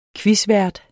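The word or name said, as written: quizvært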